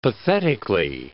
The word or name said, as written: pathetically